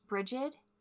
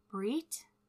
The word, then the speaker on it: Brigid: Her name may be pronounced Brigid, Brigitte, Breet, and Brige, depending on the country of origin.